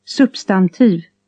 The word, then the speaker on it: substantiv: B is pronounced P. – Substantiv.